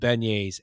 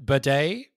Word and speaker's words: beignet: Beignet, not Bignet or something like that, because there are several people that have or as the FLG staff judge pronounced it, bidet.